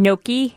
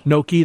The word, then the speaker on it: gnocchi: Gnocchi, I don't know how you pronounce it. – Gnocchi. – Gnocchi, that's it. Thank you.